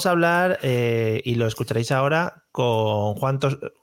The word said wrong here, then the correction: Juan Soto Ivars: ya lo digo mal por los nervios. Juan Soto y Bars eh,